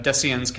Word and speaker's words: Deciens: Dan Dan uh, has a, or is part of uh, uh Deciens Capital. Am I saying that right? Of course My Latin is a little rusty.